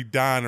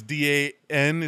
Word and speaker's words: Dan: But if you're a fifth degree black belt, third degree Don or whatever, third degree Don, and it, it's D A N is how it's spelled. Yeah, but I call it Dan.